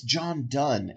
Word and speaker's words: Donne: I was going to say, it's John Donne is how you say it.